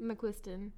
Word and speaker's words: McQuiston: How do you pronounce Casey McQuiston? McQuiston? – McQuiston. – Yeah.